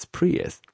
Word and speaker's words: Prius: But anyway, during last week's show, I said that I checked out a friend of mine's new car, the Prius, Toyota Prius. Is it Toyota? Yeah, Toyota Prius. And I think I called Prius Prius, which is really not Prius, it's Prius.